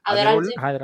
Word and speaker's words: Adderall: no sé cómo se pronuncia, Aderal o Adderall, Ajá.